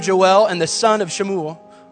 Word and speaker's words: Shemuel: The son of Shemuel, or pronunciation, our pronunciation would be Samuel, the prophet.